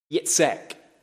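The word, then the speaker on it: Yitzchaq: And when this wee boy was born, we read in verse 3 that Abraham called the name of his son Yitzhak. That's what it is in the Hebrew. I'll spell it for you. It's Y-I-T-Z-C-H-A-Q.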